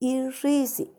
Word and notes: ixisi: Macuiltianguis

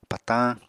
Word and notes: patañ: Apatani

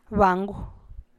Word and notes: bigidy xigie: Teotitlán del Valle Zapotec

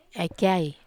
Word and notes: ai kiai: Wamesa

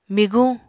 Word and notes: miguñ: Apatani

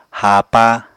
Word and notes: haapa: Apatani

